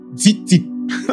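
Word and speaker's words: zitzit (ציצית): And the fridges are zitzit. That's how it's pronounced. Zitzit.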